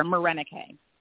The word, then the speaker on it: Morenike: Sure, Morenike.